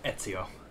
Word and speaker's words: Ezio: You just pissed off a lot of people saying that name wrong. – What part did I say wrong? – It's Ezio.